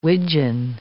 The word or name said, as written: wigeon